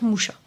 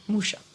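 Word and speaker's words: Mucha: Donc, je vais dire Moucha. – D'accord, on va dire Moucha. – Moucha. On va rester bien français et prononcer français.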